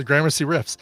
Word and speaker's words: Gramercy Riffs: I'm gonna go with the Warriors, but they always go against, and I can never say this right, so I'm gonna butcher it, but it's like the Gramercy Riffs or the Grandma Cry Riffs.